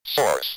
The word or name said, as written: source